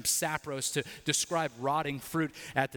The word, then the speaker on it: sapros: Actually, the Greek word that's used here is a word, something along the lines of sapros. I'm probably pronouncing it wrong, but it's S-A-P-R-O-S, sapros, which literally translated means worthless.